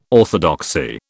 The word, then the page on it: orthodoxy: British English